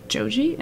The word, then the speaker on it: Joji: Anyway, this next song is Your Man by Joji.